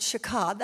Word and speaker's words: shachah: But the Hebrew word is shakah, S-H-A-C-H-A-H, and I pronounce it shakah. That's the southern pronunciation for that word.